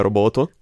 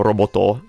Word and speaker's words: Roboto: Ufficialmente sarebbe Roboto, un po' come Polito, però ovviamente diciamo Robotò alla fine, non ci accaniamo su nessuno.